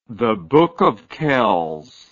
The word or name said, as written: Book of Kells, The